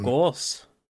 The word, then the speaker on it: Gragas: Sånn som det jeg lærte i siste episode, er at eh, Gragas uttales egentlig grågås. Grågås. Nei, ikke, ikke grågås engang. Grågås, for det grågås? kommer fra Grågås, for det kommer ifra Eller uh, Champion ble oppkalt etter Grey Goose.